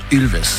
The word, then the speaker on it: Ilves: Et ça se prononce Ulves.